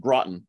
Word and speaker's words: Groton: So first, it's pronounced Groton, but only people who live here know that. So that's how I can always tell if someone's local or not.